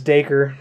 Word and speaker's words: Dacre: Dacray? – Dacray? – Oh. Dacre? – I think it's Dacre.